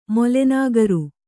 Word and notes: ಮೊಲೆನಾಗರು: Kannada

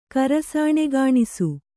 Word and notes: ಕರಸಾಣೆಗಾಣಿಸು: kannada

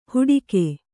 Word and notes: ಹುಡಿಕೆ: huḍike

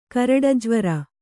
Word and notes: ಕರಡಜ್ವರ: karaḍa jvara

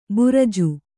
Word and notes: ಭರ್ಜಿ: bharji

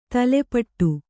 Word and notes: ತಲೆಪಟ್ಟು: tale paṭṭu

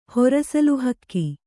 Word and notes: ಹೊರಸಲುಹಕ್ಕಿ: Kannada

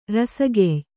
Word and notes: ರಸಗೆಯ್: rasagey

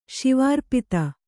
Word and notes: ಶಿವಾರ್ಪಿತ: Kannada

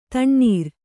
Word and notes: ದಿನಾರು: Kannada

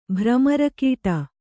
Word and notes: ಭ್ರಮರಿಗುಡು: bhramariguḍu

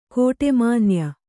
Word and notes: ಗೌಡುಮಾನ್ಯ: gauḍu mānya

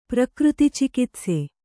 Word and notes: ಪ್ರಕೃತಿಚಿಕಿತ್ಸೆ: prakřti cikitse

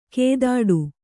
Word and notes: ಕೇದಾಡು: kēdāḍu

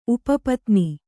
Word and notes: ಉಪಪತ್ನಿ: upa patni

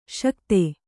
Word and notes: ಸೈಕತ: saikata

